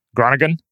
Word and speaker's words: Groningen: Groningen.